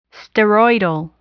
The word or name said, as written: steroidal